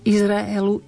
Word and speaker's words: Izraelu: Izraelu, Izraelu. Pozor, pekne pomaly, Izraelu. Nespájame nič, tam sa nesplýva, žiadna hláska nezaniká.